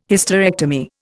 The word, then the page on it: Hysterectomy: Indian